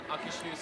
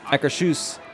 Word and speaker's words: Akershus: This one's, how do you pronounce Akershus? Let's look. I was trying to do it. – Akershus. Here's someone from the restaurant saying it. – It's called Akershus. – Akershus. – Akershus.